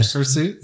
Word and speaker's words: hirsute: Is that how, that's how it's this pronounced, is, right? Yeah. Hirsute? – Yeah.